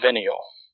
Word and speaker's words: venial: But the Catholics separate sins as mortal and venial. – Mm-hmm. – Saying that right. Is it venial? – Venial.